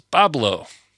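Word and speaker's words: Bois Blanc: We have another island up there in that same area called Boyce Blanc is how it's spelled, but how it's pronounced is Bablo.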